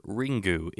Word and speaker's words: Ringu: So 1998's Ring, or Ringu, if you're pronouncing it like you're a Japanese person.